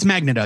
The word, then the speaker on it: Magneto: It's Magneto, yeah.